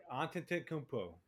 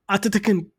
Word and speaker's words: Antetokounmpo: Antetokounmpo. Antetokounmpo. – I kind of stuttered there in the middle. Antetokounmpo.